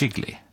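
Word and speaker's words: Gigli: Vi ska prata om allt från giggly, uttalande så, eller är det jiggly? – Jiggly, men det uttalas på lite olika sätt i den här filmen.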